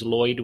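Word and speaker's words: Loyd: So my last name is Lloyd with one L, L-O-Y-D.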